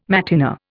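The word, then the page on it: matinée: Indian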